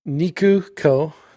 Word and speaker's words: Nikuko: But I think it's the two of them, the mom and the daughter, Kikuru and Nikuru. Am I saying it wrong now? – You're saying it wrong. – I knew this was going to happen. – Nikuko. – Oh, Nikuko and Kikuko.